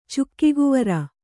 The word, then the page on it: ಚುಕ್ಕಿಗುವರ: kannada